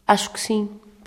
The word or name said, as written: acho que sim